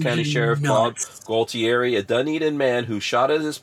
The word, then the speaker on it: Dunedin: This is Dunedin. That's how you pronounce that, right, Patrick? – Dunedin.